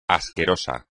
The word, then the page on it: asqueroso: espagnol